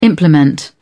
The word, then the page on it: implement: British English